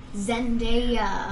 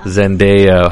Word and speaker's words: Zendaya: Zendaya or Zendaya? – Oh my God. – I always forget how to pronounce her name. How is it? – Zendaya. – Zendaya.